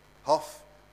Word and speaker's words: Hoff: Hoff. Amen. It rhymes with toff. Anyone knows what a toff is? T-O-F-F.